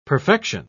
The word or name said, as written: perfection